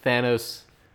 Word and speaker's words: Thanos: Thanos, how do you say it? – Thanos. Yeah, because it comes from Thanatos, the god of death.